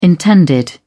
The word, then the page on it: intended: British English